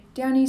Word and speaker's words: Downey: Downy spelled like the soap but with an E before the Y.